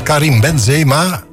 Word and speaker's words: Karim Benzema: Hoewel de namen van de laatste gebruikers, zoals Karim Benzema, zeg ik dat goed? – Ja, dat zeg je goed.